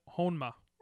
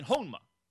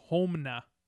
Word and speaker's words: Honma: I was saying Honma. – I think you were actually putting the M before the N. You were saying Homna.